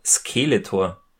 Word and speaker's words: Skeletor: Ja, es ist sehr interessant zuzuhören, der HG Francis, der sagt nämlich immer Skeletor. Also er betont das erste E, er betont die erste Silbe, das erste E so ein bisschen länger, so ganz klassische deutsche Betonung, und es klingt aber irgendwie für mich immer wieder sehr lustig.